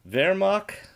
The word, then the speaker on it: Veermok: Wow, so in the comic book, it's spelled V-E-E-R-M-O-K, Virmok, Virmok.